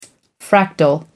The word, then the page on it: fractal: American English